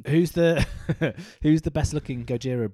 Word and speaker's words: Gojira: Gojira is the correct pronunciation of Godzilla. – Ah, so Gojira.